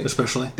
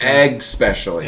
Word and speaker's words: especially: Especially. – They say EGS. – EGS. – Egg specially. – Egg specially. – It's not egg specially.